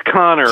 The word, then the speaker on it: Kanner: Is that how you pronounce his last name, Kanner? – It's Connor, actually.